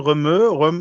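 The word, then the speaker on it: Rømø: Wir waren nämlich in wie wird es ausgesprochen, Römö. Weiß dieses R, O durchstrichenes O, M durchstrichenes O, wo man mit dem Wohnmobil Öl, ne? – Wasser fahren kann. – Das durchgestrichene O ist ein Ö, ne? – Bitte?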